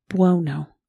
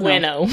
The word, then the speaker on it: Buono: Buono. – Buono. – I kept reading it as they say Buono. Bueno, because I was thinking Spanish, but he's not.